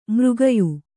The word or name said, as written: ಮರಗಯ್